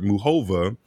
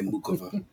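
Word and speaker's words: Muchová: Yeah, it's 8 seed Sakkari, number 10 seed in French Open runner up this year, Muhova. Shout out to Karima for always making sure I pronounce that correctly. – I hate when people say Mukova.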